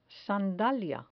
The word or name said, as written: sandalia